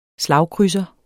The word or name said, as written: slagkrydser